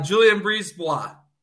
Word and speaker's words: BriseBois: I always say this wrong. Please correct me. – Julian Brisebois.